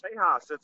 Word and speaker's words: Tejas: Yeah, Texas is pronounced Tejas. It's a Spanish word, is it not, John? – Yes, actually, it's an Indian word meaning friendly.